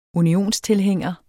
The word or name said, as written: unionstilhænger